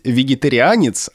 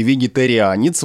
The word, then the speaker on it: вегетарианец: Дело не в ударении, а в произношении, потому что э, существует два варианта. На первом месте вегетарианец, вот это риа произносится, а на втором месте с мягким знаком, как будто бы вегетарианец.